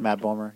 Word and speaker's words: Bomer: Matt Bomer.